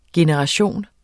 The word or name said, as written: GENERATIONERNE